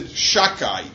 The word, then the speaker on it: Shakai: which we don't want to pronounce the Dalet either, we pronounce it Shakai.